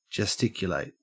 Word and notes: gesticulate: Australian English

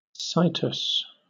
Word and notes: situs: British English

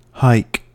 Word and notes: hike: American English

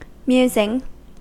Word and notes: musing: American English

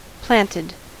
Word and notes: planted: American English

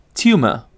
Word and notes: tumor: British English

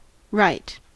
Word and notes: rite: American English

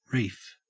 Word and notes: reef: Australian English

